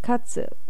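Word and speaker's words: katsu (活): It's usually pronounced katsu, as in katsudo, which is activity.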